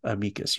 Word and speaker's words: amicus: If you took Latin, it would be pronounced amicus. You always emphasize the second to last syllable.